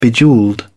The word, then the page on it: bejewelled: British English